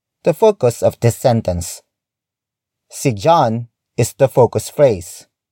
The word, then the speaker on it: si: The next word is See. See. See. See is spelled as S-I.